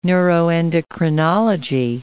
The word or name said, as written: neuroendocrinology